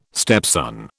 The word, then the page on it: stepson: British English